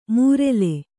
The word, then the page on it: ಮೂರೆಲೆ: mūrele